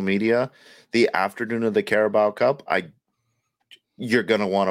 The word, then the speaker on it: Carabao: And it's not called the Caribou Cup. It's called the Carabao Cup.